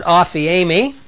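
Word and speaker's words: aphiemi (ἀφίημι): But the word forgiven here, your sins are forgiven for His sake, it's the word aphiemi, aphiemi, it's a long E.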